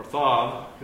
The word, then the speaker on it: Tav: Oath, we'd pronounce it like O-A-T-H in English, but it's Aleph, Vav, Tav. Or Thav, because it's a TH sound rather than just a T sound. So the Thav.